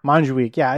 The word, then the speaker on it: Montjuïc: Montjuïc, is that right? – Montjuïc, yeah, it's close enough. Look, I'm an American.